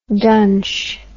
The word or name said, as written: dunch